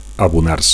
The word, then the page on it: abonar: Catalan